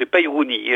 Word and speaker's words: Peyrony: Alors, Peyrouni, ça s'écrit P-E-Y-R-O-N-Y, mais nous, en Sarlandais, on prononce le Y, pas le dernier. Hein. Alors, donc, c'est Peyrouni, hein, j'ai l'habitude de prononcer comme ça, donc je vais continuer.